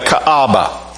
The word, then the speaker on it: Kaaba: And at Mecca, there was a temple called the Kaaba.